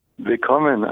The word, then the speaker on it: Willkommen: Did I say it right? Vilkomenen? There it is. – No, Vilkomen.